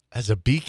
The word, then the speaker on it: Azubuike: he said you would pronounce his last name as Azubuike. – Azubuike? – Azubuike.